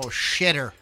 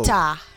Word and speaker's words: Sitta: Flagpole Shitter. – It's no. Sitta. No, to spell it right.